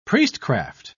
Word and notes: priestcraft: British English

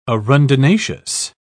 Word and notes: arundinaceous: British English

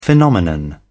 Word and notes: phenomenon: British English

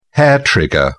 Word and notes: hair trigger: British English

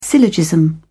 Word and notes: syllogism: British English

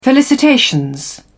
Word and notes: felicitations: British English

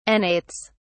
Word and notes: annates: British English

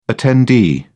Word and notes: attendee: British English

